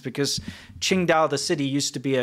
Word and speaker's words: Qingdao: And it's pronounced Qingdao.